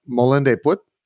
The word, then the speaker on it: Molen de Put: I think there's one called Molen de Put. – Yes. – Did I say it correctly? – Yes. Well, to me, I'm American.